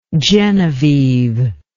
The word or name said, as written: genevieve